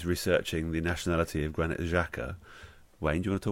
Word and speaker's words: Xhaka: I think that is someone, by the way, who should be pronounced Xhaka. I think that is a Zh.